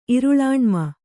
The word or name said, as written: ಇರುಳಾಣ್ಮ